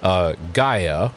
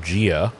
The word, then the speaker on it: Gaia: It's the root words are apogee, which is the furthest distance from, and then Gaia, which is, or Gia, which is, depending on how you want to pronounce it, is, which is Earth.